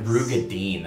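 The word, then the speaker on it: Roegadyn: Rogaiden? – Or is that how you say it? – Nope. – Who cares? I don't know how you say it. Rugadin? – Whatever. Wasn't it Rugadin? – Yes. Rugadin. That's so stupid.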